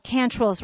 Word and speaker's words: Quantrill: During the Civil War, there was a massacre in Lawrence called Cantrell's Raid. It's spelled Quantrill, but it's pronounced Cantrell.